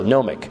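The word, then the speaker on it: gnomic: So, and nomic is not spelled with an N, it's spelled with a G. So it even makes it weirder. All right? So nomic. All right. Kind of like a gnome, like in your garden.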